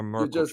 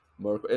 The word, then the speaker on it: Merkle: I'm using this Merkle, and Americans don't even know how to pronounce the thing. They write Merkle, like Angela Merkel, and yeah.